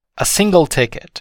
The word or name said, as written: a single ticket